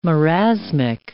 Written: marasmic

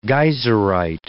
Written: geyserite

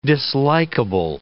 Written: dislikeable

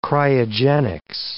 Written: cryogenics

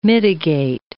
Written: mitigate

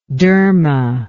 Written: derma

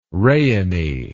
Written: rayonny

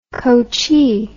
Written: coachee

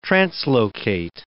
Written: translocate

